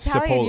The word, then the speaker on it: Cipolla: I was about to say, Mr. Italian, Cipolla.